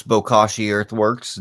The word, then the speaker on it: Bokashi Earthworks: You were pretty close. Bokashi Earthworks. Bokashi is a Japanese word for a type of anaerobic fermentation or of anaerobic composting.